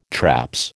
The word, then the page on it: traps: American English